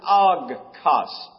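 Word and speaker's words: ogkos (ὄγκος): In the Greek, we pronounce it ogkos. O-G-K-O-S.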